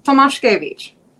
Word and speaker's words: Tomaszkiewicz: Tomaszkiewicz. – Yes. Wow. And you can say it with a bit of an accent. – Yeah, I think actually. Phonetically it would be Tomaszkiewicz.